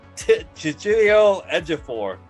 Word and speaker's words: Chiwetel Ejiofor: It's uh, Chiwetel Ejiofor.